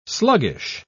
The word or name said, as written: sluggish